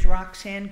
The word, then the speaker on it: Roxanne: Sure, my name is Roxanne Grunheide. And it's spelled R-O-X-A.